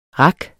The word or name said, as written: rak